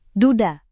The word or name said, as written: duda